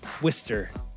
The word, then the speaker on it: Worcester: I went to undergrad school at Clark University down the road from WPI, and Worcester rhymes with Mister, so I guess it's Worcester. Rhyme with Mister, can't go wrong.